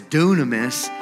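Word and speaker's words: dunamis (δύναμις): And that word power in the Greek, the New Testament is written in Greek, is spelled dynamis, and we know it as dunamis.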